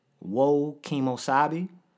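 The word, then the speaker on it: Whoa Kemosabe: So I changed it to Woe Kemosabe. – Woe Kemosabe. – And how you spell that is W-H-O-A underscore, and Kemosabe is K-E-M-O-S-A-B-E.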